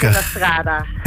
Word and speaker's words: Gymnastrada: Gymnastrada, zeg ik dat goed? Hoe het uitgesproken moet worden? Goedemiddag. – Goedemiddag, dat zeg je helemaal goed inderdaad. – Gelukkig. – Gymnastrada.